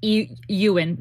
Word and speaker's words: Iwan: Yeah, Ian Rian is his name. Well, it's pronounced Ewan. Ewan Rian. I-W-A-N.